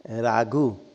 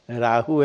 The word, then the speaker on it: राहु: ह को घ कहते राघु राहु है ना नवग्रह राहु में लिखा गया राघु इंग्लिश में लिखते राघु